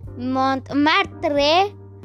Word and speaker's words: Montmartre: Bueno, ahora vamos a otro palacio al Palacio de Montramarte. Si es que así se pronuncia. Así que vayamos. Antes de decirles datos de Montmartre, que es así como se pronuncia, es la primera corrección que voy a hacer porque voy a hacer tres correcciones.